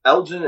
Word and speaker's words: Elgin: from, how do you say the name of the city you're in Illinois? – Elgin, Illinois. – Elgin. That's what I thought, but I didn't want to say it wrong.